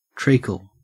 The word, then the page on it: treacle: Australian English